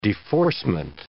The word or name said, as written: deforcement